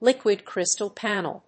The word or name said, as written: liquid crystal panel